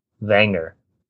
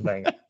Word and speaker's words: Wenger: And producer Ian says Arsene Wenger. I'm pretty sure you pronounce the W a V.